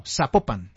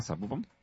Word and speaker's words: Zapopan: Municipal, no, Zapopan. – Ah, Zapopan. – Sí, con acento en la segunda, en la segunda sílaba, en la O, ¿cierto?